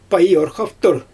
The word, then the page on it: pajurxaftur: Xyzyl